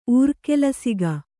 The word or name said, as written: ಊರ್ಕೆಲಸಿಗ